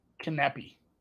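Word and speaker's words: Kanepi: Canopy and Martinsova. Is it Martinsova? I want to say that was a soft C. – It is a soft C, but it's Canepi.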